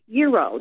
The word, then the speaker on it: Jero: I was just looking over some photographs of, how do you pronounce J-E-R-O? – Euro. That's pronounced Euro.